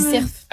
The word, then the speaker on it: cerf: Mais du coup, arrête-moi si je dis cerf. – Ah oui, cerf. Ouais. Ah, dans le sud-est, on prononce toutes les lettres. – Et dis le F.